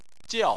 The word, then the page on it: jiao: 4